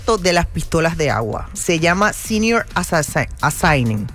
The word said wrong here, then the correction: Assassin: ¿Cómo se pronuncia? Como de asesinar. Senior... – ¿Assassination? – No. – ¿Assassin? – Assassin. Senior Assassin.